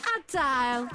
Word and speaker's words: Adele: Y es que su nombre Adele no lleva la sílaba tónica en la primera letra A. El énfasis correcto debe recaer en la segunda sílaba. ¿Qué? Y darle una entonación más delicada al integrar las otras vocales, es decir, Adele.